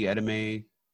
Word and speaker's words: edamame: You can get a Nobu Dallas hot bento, which is spicy edamame, California. – wait. Spicy what? – Oh, how do you say that? E-D-A-M-A-M-E? How do you say it? – Edamame. – Edamame, same thing.